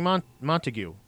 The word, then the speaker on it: Montague: Johnny Montague, by the way. Not Montague. God damn it. I said it again. It's Montag, but it's spelled Montague.